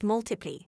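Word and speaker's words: ×: With his sophomore album, X Pronounced Multiply, Sheeran continued his trajectory of success.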